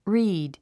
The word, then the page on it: reed: ríːd; リード